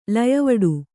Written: ಲಯವಡು

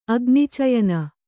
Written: ಅಗ್ನಿಚಯನ

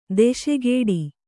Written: ದಾಸಗೌಡ

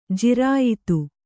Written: ಜಿರಾಯತ

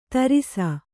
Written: ಧಾರಿಸು

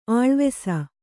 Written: ಆಳವಿಸು

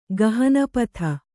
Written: ಗಹನಪಥ

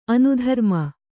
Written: ಅನುಧರ್ಮ

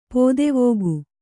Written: ಪುದುವೋಗು

